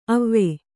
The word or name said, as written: ಅವಿ